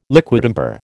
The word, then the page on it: liquidambar: American English